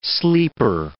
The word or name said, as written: sleeper